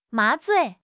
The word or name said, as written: 麻醉